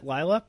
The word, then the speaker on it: Lila: Is that how you pronounce it? Lila? – Yeah, because if you yell Lila, I'm going to look up because it sounds like the end of Delilah.